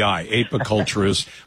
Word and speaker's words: apiculturist: Ape like monkey, but API, apiculturist.